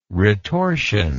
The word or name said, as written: retorsion